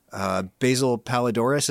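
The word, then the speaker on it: Basil Poledouris: Basil Palidoris, is that how you say his name? – Yeah.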